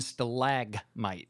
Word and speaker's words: stalagmite: It's stalac with a C, tight. – Oh, really? – And stalag with a G, mite. – So yeah. Stalac, stalactite, and stalagmite.